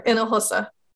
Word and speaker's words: Hinojosa: If you want me to make it easy, just it's like three syllables. It's four. Hinosa.